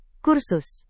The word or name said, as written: kursus